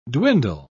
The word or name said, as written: dwindle